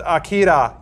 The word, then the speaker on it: Akira: Also, it's pronounced Akira, you plebs.